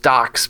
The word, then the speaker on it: Docx: Apparently, it's pronounced Docs, because I did the same thing you did. I saw like the Microsoft Office extension.